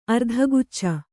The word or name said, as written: ಅರ್ಧಗುಚ್ಚ